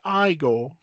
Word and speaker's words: Igor: What gets me is that, and then I found this out later, is Igor. – No, it's pronounced Igor.